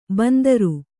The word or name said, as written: ಬಿಂದಾರಿ